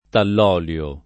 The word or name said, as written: tallolio